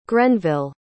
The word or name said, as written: grenville